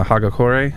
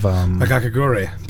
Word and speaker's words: Hagakure: It gave them a bunch of Hagakure. Sorry, yeah. I said that wrong.